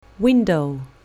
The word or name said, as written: window